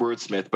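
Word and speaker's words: WRDSMYTH: And it's pronounced like, well, it's pronounced Wordsmith, but it's spelled like WRDS and then MYTH.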